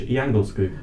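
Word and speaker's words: Djungelskog: It's pronounced in Swedish, Jangleskog.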